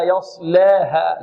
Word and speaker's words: يصلاها: اللام نحافظ على فتح اللام، والألف هنا بارك الله فيكم لا إمالة فيها. طيب والهاء مرققة، واضح. إذن لا يص، إذن هذه الكلمة ننطقها هكذا لا يصلاها.